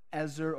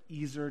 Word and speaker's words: Ezer: And the word literally translates to Ezer or Ezer, depending on how you want to pronounce it, this ancient Hebrew word.